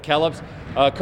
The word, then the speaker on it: McKellips: I don't even know how to pronounce his last name. McKellips, is that? – Yes. – Yeah, McKellips. Coach McKellips preaches defense because every time I come here, you guys are suffocating.